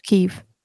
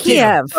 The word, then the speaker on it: Kyiv: Is it Kyiv or Kyiv? – It's Kyiv. – It's Kyiv. – It's Kyiv. – There you go, Abby. You freaking fed. – I'm sorry. Unless you want to start pronouncing France as France, I legitimately Germany didn't know, which is why I asked. – We're American, it's Kyiv.